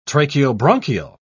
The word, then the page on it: tracheobronchial: British English